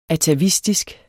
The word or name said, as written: atavistisk